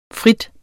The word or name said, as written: fri